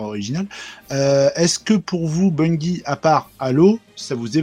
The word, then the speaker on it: Bungie: Alors, comment ça se prononce Bungie Bungie, ouais, tout simplement.